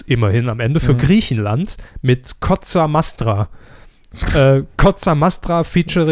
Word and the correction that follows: Mostra: Das ist doch ein O, Mostra.